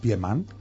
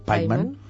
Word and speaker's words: Piemann: I a tu, et toca fer el paper de secretària de Klaus Piemann. – Paiman.